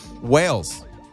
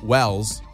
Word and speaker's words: Wales: Whales are the animal. – Wales. – Wales. – Wales is the country, yeah. – Wales. – Yeah, much maligned country. – Wales.